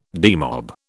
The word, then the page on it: demob: British English